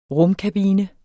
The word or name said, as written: rumkabine